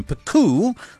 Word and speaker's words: Picoo: Now, the COVID lockdowns didn't help either, which is why the game Pico caught my attention. It's spelled P-I-C-O-O.